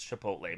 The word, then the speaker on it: Chipotle: And I'm like, I know I said it wrong. It's Chipotle. But no, it's just fun.